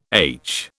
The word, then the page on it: aitch: British English